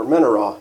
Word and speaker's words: menorah: And of course, it's got the menorah, or menorah, if you want to say it right.